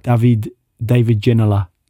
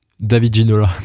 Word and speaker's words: David Ginola: David Ginola. – David Ginola. David Ginola. – You should get Baptiste to do proper French names and how they're said in England and stuff. David Ginola plays for Newcastle, eh?